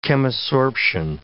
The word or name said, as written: chemisorption